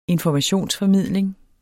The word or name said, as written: informationsformidling